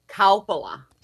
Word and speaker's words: Coppola: Coppola. – See? Okay. – Coppola. – Cow like Coppola. Moo. Coppola.